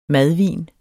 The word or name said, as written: madvin